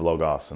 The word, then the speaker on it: Logos: He's from with a more in-depth discussion on the history of Logos and the Logos of history, but he pronounces it Logos because he's from Philadelphia. So I'll just honor his work by using his pronunciation.